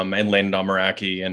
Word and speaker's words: Meraki: But you probably pronounce it Rack. What would be the Boston thing? Rock. – Not how you pronounced it. Meraki.